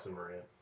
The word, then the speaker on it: Cimmerian: It wrong in this movie, but it's pronounced Cimmerian. – Is it? – Yeah. – Well, I thought it would be like Cimmerian since it's Irish or Celtic, because C's are pronounced like K's a lot of the time.